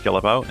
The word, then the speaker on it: Guillebeau: My profile on LinkedIn is just my name, Chris Guillebeau, and that last name is spelled G-U-I-L-L-E-B-E-A-U.